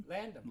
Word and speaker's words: Landham: I hope they do a Sonny Latham... Is that how you pronounce his name? – Landham. – Landham. Yeah. Landham, right. Since there's no T in H, it probably wouldn't be Latham.